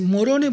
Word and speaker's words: Morone: Bueno, yo le iba a decir, ¿cómo se dice? Morone, porque es una sola R.